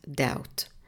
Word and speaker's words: doubt: Következő szavunk a doubt, aminek kiejtése nagyon hasonlít az előző debt főnévéhez. Ugyanis a szó végén egy BT kapcsolat van itt is, ahol is a B hangot nem ejtjük, tehát néma. Kiejtése tehát doubt.